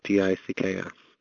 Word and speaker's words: Dicks: My name is not spelled like the fort, Dix. It is spelled like the other way that you would spell Dix, Dicks.